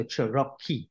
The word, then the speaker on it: Cerocchi: They pronounce the CH in Italy as a K sound, so Cerocchi.